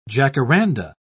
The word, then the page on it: jacaranda: British English